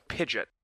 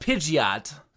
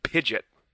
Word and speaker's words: Pidgeot: You know, their main Pokemon is a Pidgeot. That's like the Pokemon that they're using, is a Pidgeot. – It's pronounced Pidgeot. – Pidgeot.